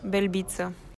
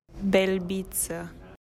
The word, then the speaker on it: Belbita: b-e-l-b-i-t-a so it looks a lot like Belbita on paper. Cody Merrow, of whom am I speaking? – Well, I feel like you said that. Now I'm going to say it incorrectly, but I think it's Diana Belbita. All right, let's hear her say it. – Diana, the warrior princess Belbitsa. – Wait for it.